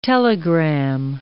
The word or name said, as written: telegram